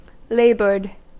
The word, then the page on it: laboured: American English